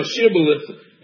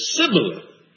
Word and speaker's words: Shibboleth: They pushed him and they said, say now Shibboleth. And he said Shibboleth, not without the H. He mispronounced it.